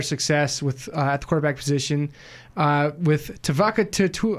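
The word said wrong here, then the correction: Tuioti: I'm going to pronounce this horribly. Sorry, Tuoti. There we go. Got it that time.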